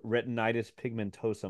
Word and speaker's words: retinitis pigmentosa: You were talking about having like a Google search for retinitis pigmentosa.